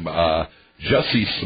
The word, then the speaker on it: Jussie: I was mispronouncing his name, Jussie Smollett. I've been saying Jesse all along.